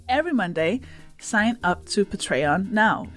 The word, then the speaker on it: Patreon: Patreon, which we pronounce Patreon because that's how my mom says it.